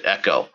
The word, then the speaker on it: Eco: Well, again, you know, unlike, you know, EcoBoost, you know, when they launched this thing, you know, they very, very explicitly took care to pronounce it Echo,